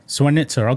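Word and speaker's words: Zwonitzer: Her said in a report that Biden disclosed classified material from his notebooks to the ghostwriter, Mark Zwanzer? I don't know how you pronounce the name, but anyway. – Zwanzer. – How you pronounce it? – Zwanitsa, okay.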